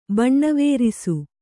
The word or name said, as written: ಬಣ್ಣವೇರಿಸು